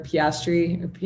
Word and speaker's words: Piastri: I was listening to F1 Nation's recap and they kept talking about how hype people are on Oscar Piastri. That's how you say it, right? – Yeah.